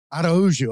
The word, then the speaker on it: Araujo: Did I get that right? – Yes, it's Araujo. – Araujo. Okay, I was close.